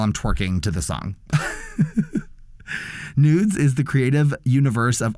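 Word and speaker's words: NVDES: The song I selected for this category is The Goddess Way by Nudes, which is how it's pronounced, but it's N-V-D-E-S.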